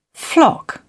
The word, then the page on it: flock: British English